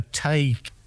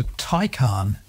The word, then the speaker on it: Taycan: I might have said a Tay, but it's not that, is it? – No, it's a Taycan. – T-A-Y-N.